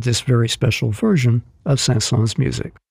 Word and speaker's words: Saint-Saëns: It is pronounced Saint-Saëns.